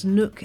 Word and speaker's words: Nook: That's Nook as in rhymes with book, is definitely worth a listen.